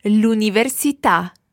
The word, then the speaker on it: l'università: Now we've got to watch our stress in this one. Let's hear it again. – L'università. – L'università. – L'università. Good. The stress goes on the last syllable there.